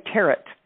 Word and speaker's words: tarot: And he said, you didn't say tarot. You knew not to pronounce the T.